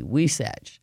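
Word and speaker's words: huisache: How do you spell weesatch tree? That's a very good question, and it is spelled H-U-I-S-A-C-H-E. H-U-I-S-A-C-H-E, weesatch.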